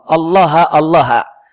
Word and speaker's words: Allaha Allaha: Allaha Allaha. Ini bacanya dengan nasab. Namanya di nahwu namanya tahdzir.